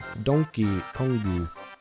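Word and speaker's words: Donkey Kongu (ドンキーコング): The enemy's name was also changed, with the Japanese name being Dosuke Yongu, which sounds like a bootleg version of how Donkey Kong is pronounced in Japanese. Donkey Kongu.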